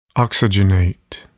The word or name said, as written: oxygenate